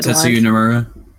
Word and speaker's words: Tetsuya Nomura: Like, I've only heard Tetsuya Nomura.